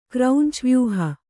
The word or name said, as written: ಕ್ರೌಂಚವ್ಯೂಹ